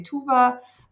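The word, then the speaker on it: Tuğba: The way it's written, T-U-G-B-A with the silent G, it's the Turkish way. So I'm half Turkish, half Greek. But actually the name comes from Arabic. But of course, like the Arabic pronunciation is a bit different. It's Toba. In Turkish, we say Tuba.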